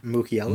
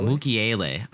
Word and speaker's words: Mukiele: Mukiele. – Mukiele.